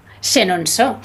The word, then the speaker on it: Chenonceau: Chenonceau, muy bien, muy buena la pronunciación, Bueno, sí.